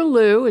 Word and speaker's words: Lieu: My name is Barbara Liu. It's spelled L-I-E-U. It's in lieu of my old name, or in lieu of being straight.